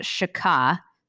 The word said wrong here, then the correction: shacha: Is that right? – I'm gonna throw in a little phlegm here, you know, as a good Jewish man. Here it is, shacha, way deep down in the throat, shacha.